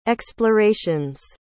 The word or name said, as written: exploration's